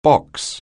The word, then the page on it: box: anglais